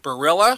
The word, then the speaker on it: Barilla: What I find interesting, Brenda, is you sent me pictures of these two pastas, and a very familiar pasta brand is Barilla.